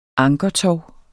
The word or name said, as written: ankertov